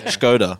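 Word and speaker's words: Škoda: And did you pronounce it Skoda? – Yeah, is that Skoda. The correct? It is Skoda? – Skoda. – You can inform me on that one. – Yeah, Skoda.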